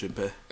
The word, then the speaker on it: Choupette: I don't know how to pronounce his cat's name. Like, it's Chapepe or some shit like that? Chapepe? Chupete. – Is it French? – It's Choupette. – Yo, all my French people out there, it's probably pronounced Chupé.